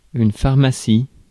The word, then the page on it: pharmacie: France; /faʁ.ma.si/